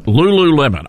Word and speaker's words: Lululemon: Lululemon.